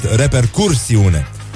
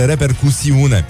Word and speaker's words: repercusiune: Primul ar fi repercusiune pe care românii, nu-i așa, uh, echidistanți cum îi știm și toleranți, o prezintă uh, drept repercursiune. Da? Forma corectă este repercusiune. Și uh,